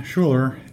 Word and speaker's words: Schuler: Okay, it's Ron Schuler, S-C-H-U-L-E-R.